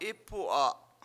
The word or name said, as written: Ipu7a